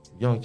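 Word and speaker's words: Yung: Well, you can get it on Spotify, Young Chuck, or, you know, I'm on all platforms, Young Chuck. Y-U-N-G. A lot of people still doing the Y-O-U-N-G.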